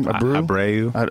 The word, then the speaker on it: Abreu: How do you say his last name? – Abreu. – I've only seen it written. I've never actually said it. Abreu? – I've heard it.